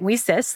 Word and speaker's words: WiCyS: We often go by our acronym, WICYS, and we pronounce that WISIS, like We Sisters, because we're a global cyber sisterhood.